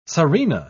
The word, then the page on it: tzarina: British English